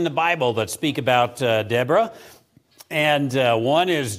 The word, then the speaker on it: Deborah: Deborah is how you say it in Hebrew, and we will learn about this second of the female prophets.